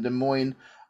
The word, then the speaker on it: Des Moines: Fingers crossed, I get to see Roderick Strong versus Speedball Bailey in Des Moines, Iowa in July.